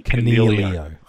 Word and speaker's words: Coniglio: for those people that get driven crazy, you don't pronounce the G in Cornelio. – Oh, what? – It's a silent G.